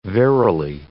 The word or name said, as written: verily